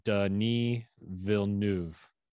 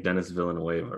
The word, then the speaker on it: Denis Villeneuve: Denis Villeneuve.